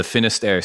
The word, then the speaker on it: Finisterre: And even the industry, you know, you developed the I'm going to screw the name up, the Finisterre Sea Suit. Did I say that right? – Mm-hmm. Perfect.